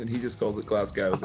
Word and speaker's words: Glasgow: Tell him not to pronounce Glasgow as Glasgow, as every wrestler does. Glasgow.